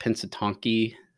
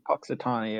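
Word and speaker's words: Punxsutawney: So anywho, but, and I guess, how do you say it? Pensatonky Phil? – Puxatonny, I think. – Yeah. Puxatonny, yeah.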